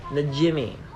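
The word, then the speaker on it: Najimy: Najimny. I'm saying it wrong again. Najimmy. – Najimmy. – Najimmy. – Like the difference between chimney and chimney.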